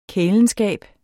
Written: kælenskab

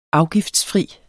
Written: afgiftsfri

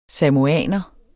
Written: samoaner

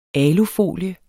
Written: benyt

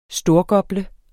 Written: STORGOPLE

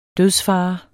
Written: dødsfare

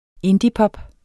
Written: indiepop

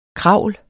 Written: KRAVL

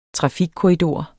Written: trafikkorridor

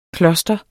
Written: kloster

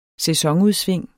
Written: sæsonudsving

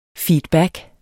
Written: feedback

